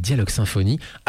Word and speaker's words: Dialogue Symphonie: Deux des groupes, Versailles juste à l'instant et Moi dis-moi juste auparavant avec Dialogue Symphonie, à prononcer à la française s'il vous plaît,